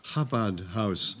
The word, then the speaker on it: Chabad: So I knew then, I better pronounce it right, not a bad house, Chabad House, ultra-Orthodox.